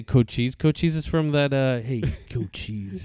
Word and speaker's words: Cochise: based his beliefs on what happened in Cochise County Sheriff's Office. – Cochise? – Cochise.